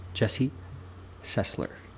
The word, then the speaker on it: Jesse Sesler: I'm your host, and joining me on today's podcast is my friend, Jesse Sessler.